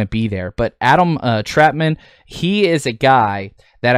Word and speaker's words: Trautman: Trautman, I believe is how it's pronounced, not Trout, sorry.